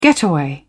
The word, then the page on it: getaway: British English